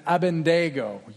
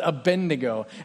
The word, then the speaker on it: Abednego: We're going to read a story about three men in particular, Shadrach, Meshach, and Abednego. You, probably like me, grew up hearing it referred to as Abednego, and that might still be how I say it as we go, but it's actually pronounced Abednego.